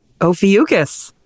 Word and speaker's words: Ophiuchus: Ophiuchus.